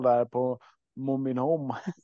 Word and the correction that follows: Mom I'm Home: ja, den heter ju Mom I'm Home.